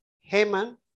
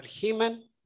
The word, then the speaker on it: Heman: The title of the psalm says that this is a prayer by Haman, or Heman, the way you want to pronounce it, by Heman, the Ezrahite.